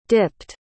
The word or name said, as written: dipped